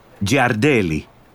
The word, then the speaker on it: Giardelli: Giardelli.